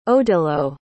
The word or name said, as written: odilo